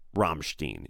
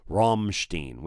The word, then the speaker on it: Rammstein: Somebody said it's R-U-M-M-Stein, Rammstein, which I think is right. But another guy said it's Ramstein. So I think it's Rammstein.